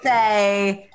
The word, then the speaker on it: Rothe: Also, Matthew Mercer's voice has confirmed me it is Rothe. – Oh, is it? Does he pronounce it on D&D? – Still sounds bad to me. – That's how it's pronounced on D&D Beyond. – Rothe.